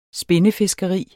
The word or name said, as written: spindefiskeri